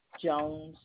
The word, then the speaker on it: Jones: You said T-A-M-E-L-A? P-A-M-E-L-A. – Yeah, P-A-M-E-L-A. Yes, ma'am. – And last name? – Jones, J-O-N-E-S.